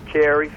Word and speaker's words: Cary: You can find me under my real name, Carrie, C-A-R-Y, Calvin, C-A-L-V-I-N, or under Game Changer, and it's spelled the unique way, GAIMCHNG3R.